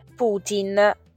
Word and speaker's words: Putin: Ebbene, c'è anche un'altra parola che si pronuncia praticamente allo stesso modo e si scrive in maniera quasi uguale, ed è Putin, P-U-T-I-N, quindi alla fine finisce con tin, no, Palestine, quindi senza A.